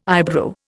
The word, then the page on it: eyebrow: Indian